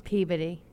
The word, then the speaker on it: Peabody: And of course, in Boston, you pronounce Peabody as Peabody.